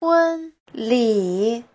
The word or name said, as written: hun1li3